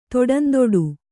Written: ತೊಡಂದೊಡು

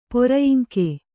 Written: ಪೊರಯಿಂಕೆ

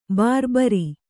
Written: ಭರಭಾರಿ

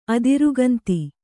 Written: ಅದಿರುಗಂತಿ

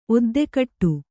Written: ಒದ್ದೆಕಟ್ಟು

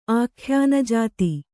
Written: ಆಖ್ಯಾನಜಾತಿ

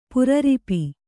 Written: ಪೇರಿರ್ಪೆ

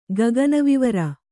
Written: ಗಗನವಿವರ

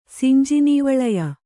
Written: ಸಿಂಜಿನೀವಳಯ

